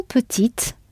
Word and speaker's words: petites: Quand nous étions petites. I heard that t sound at the end.